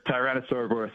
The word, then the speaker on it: Tyrannosauroboros: Can you tell us about your second album, Tyrannosauroboros? Is that how you pronounce it? – That's correct. Tyrannosauroboros.